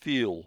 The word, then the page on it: feel: British English